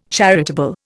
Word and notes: charitable: Indian

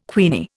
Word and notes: queenie: Indian

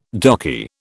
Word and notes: darky: British English

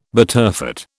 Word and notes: butterfat: British English

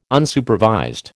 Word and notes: Unsupervised: American English

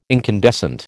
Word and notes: incandescent: American English

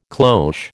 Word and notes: cloche: American English

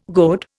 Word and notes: goad: Indian

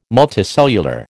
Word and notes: multicellular: American English

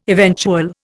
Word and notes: eventual: Indian

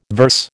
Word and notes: verse: American English

